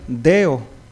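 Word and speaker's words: deo (δέω): Entonces, la palabra atar, hermanos, según el griego, es una palabra que se pronuncia deo, D-E, con un acento arriba, O.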